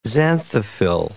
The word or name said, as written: xanthophyll